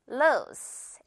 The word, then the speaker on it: loose: lose 아니고 loose, S로 끝내볼게요.